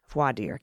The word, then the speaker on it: voir dire: Voir dire, got it.